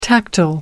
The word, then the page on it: tactile: American English